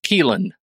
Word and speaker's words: Caoilfhionn: It's Welsh, and I'm pretty sure it's Welsh, C-A-O-I-L-F-H-I-O-N-N, Dunn. And it's pronounced Keelan.